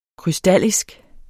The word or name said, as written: krystallisk